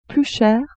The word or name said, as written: plus cher